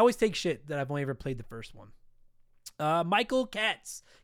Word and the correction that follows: Cates: Cates? We have Daryl Cates here in the city. It's spelled the same way. So you're getting pronounced Cates.